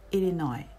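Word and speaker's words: Illinois: そしてイリノイ、イリノイ州ですね。中西部の大きな州です。これも、最後の S は発音しません。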